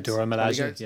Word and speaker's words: Dora Milaje: The Dora Milaje, yeah.